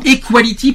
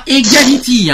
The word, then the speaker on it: Equality: Alors, je, je, je, prononce bien le mot equality parce que toute la semaine, on nous a bassiné en, en, transformant le nom de l'association en egality. C'est pas egality, hein. C'est equality. equality. E Q. Equality.